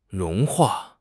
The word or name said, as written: 融化